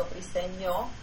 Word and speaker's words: Briseño: It's an N in Spanish, so Briseño.